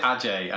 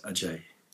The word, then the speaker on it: Ajay: Or is it AJ? I never quite. Um, the English, yeah, the English pronunciation, I suppose, is AJ, kind of growing up in, in, in London, but uh, Indian pronunciation is Ajay.